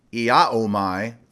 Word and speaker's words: iaomai: In the Greek, that word is pronounced iaomai,